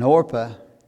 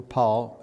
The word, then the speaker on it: Orpah: And Orpah, or Paul, which way you want to pronounce it, kissed her mother-in-law, but Ruth clave unto her.